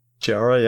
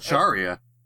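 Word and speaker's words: Cheetara: And then Chitara from Thundercats, I'm gonna try to pronounce her name, Chiara? – Chiara, yeah. – Chiara? Okay, I didn't have to. – I thought it was Charia.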